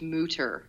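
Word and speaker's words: Mütter: He says it's Mutter. My mouth just doesn't work that way. I can't form that word.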